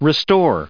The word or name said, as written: restore